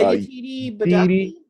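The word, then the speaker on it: Yetide Badaki: Yeah, Yatidi Badabi.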